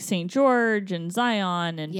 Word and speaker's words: Zion: Because it's Zion. – Zion. – Like lion.